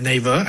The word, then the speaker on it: Naver: Well, first of all, I pronounce it as Naver. I hope that's correct.